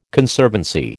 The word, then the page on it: Conservancy: American English